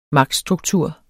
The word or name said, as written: magtstruktur